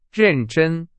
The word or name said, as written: 认真